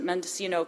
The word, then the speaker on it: Mendocino: She teaches at Mendocino College and is the director.